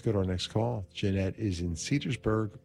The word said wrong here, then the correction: Cedarburg: Wisconsin. I'm sorry, Cedarburg. Did I say that right?